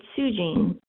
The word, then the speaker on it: Sujean: I like to say it's Sujean, like blue jean, but instead of blue, it's Sue without the E.